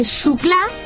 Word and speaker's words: Xucla: Y si no tiene correo electrónico, nos escribe a la siguiente dirección poniendo programa Radio Benjamín, calle Xucla, se escribe con X, calle Xucla. – Como Xavier. – Como Xavier. O xilófono.